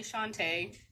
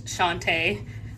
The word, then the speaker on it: Shante: So I'm Allie Shante. That is how you pronounce it, is Shante.